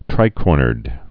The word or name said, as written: tricornered